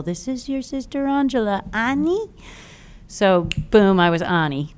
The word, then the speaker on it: Ani: You know, a little bit weird for the Americans to pronounce, you know, because everybody says Annie, and so it's a lifetime of correcting or not, just right.